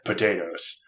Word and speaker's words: Pot-8-O's: Yeah, I like the deadpan introductory paragraph of the article that says, he is best known for the unusual spelling of his name, pronounced potatoes.